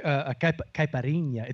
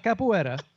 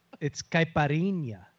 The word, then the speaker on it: caipirinha: It's not capoeira. It's not dance fighting. It's caipirinha. – Caipirinha. – Caipirinha. I'll teach you how to say it.